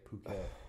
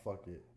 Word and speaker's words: Phuket: Phuket. It's not fuck it.